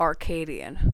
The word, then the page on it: arcadian: American English